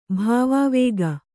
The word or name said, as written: ಭಾವಾವೇಗ